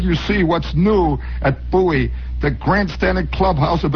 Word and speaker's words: Bowie: They're up and running here Monday, Friday, January 11th at Bowie Race Course. – By the way, it says in here, rhymes with Dewey.